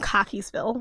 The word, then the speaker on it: Cockeysville: I'm from Cockeysville, which is a suburb of Baltimore. Cockeysville? – Yes, yeah. And it's spelled exactly like you think it is.